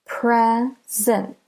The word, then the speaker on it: present: Present. All right, so there are two syllables. Do you hear? Present. Present. Present. So the first syllable is pre, and the second syllable is sint.